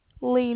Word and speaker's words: Lina: Lena. – Come on now. Lena? – Yes. – Okay, spell that for me. – L-I-N-A.